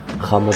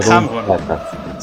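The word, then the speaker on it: Ħamrun: How do you pronounce it? Hamun or Hamun? – with the Hamrun. – Hamrun.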